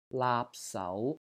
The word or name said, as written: laap3sau2